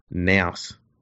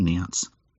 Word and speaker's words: nous: Yeah, and I called it nous, not nounce. Is it nous or nous? – Nous. It rhymes with mouse.